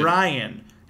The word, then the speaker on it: Brian: I said, no, it's Brian. Emphasize the Bri. Like, yeah, Brian.